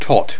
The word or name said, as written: tot